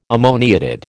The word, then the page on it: ammoniated: American English